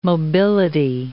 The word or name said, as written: mobility